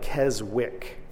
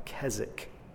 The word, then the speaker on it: Keswick: It's spelled like Keswick, if you ever see it, but it's pronounced like Keswick.